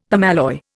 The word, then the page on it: permalloy: Indian